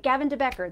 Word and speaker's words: Gavin De Becker: And that is by Gavin De Becker. I get it mixed up sometimes. I said it right. Gavin De Becker, The Gift of Fear.